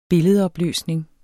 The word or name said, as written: billedopløsning